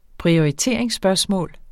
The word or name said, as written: prioriteringsspørgsmål